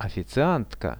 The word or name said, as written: oficiantka